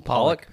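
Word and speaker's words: Pollak: How are you supposed to say his name? Pollock? – Yeah.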